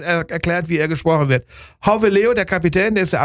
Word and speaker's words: Gouweleeuw: Das ist ein Niederländer, Jeffrey Hauweleo wird er ausgesprochen. Geschrieben wird er G-O-U-W-E-L-E-E-U-W. Und er bereitet mir seit Jahren Probleme. Ja, und er wird gesprochen Hauweleo.